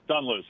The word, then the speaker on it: Dunluce: Oh, is it pronounced Dunluce Links course in Northern Ireland? – Yeah, Dunluce, yeah.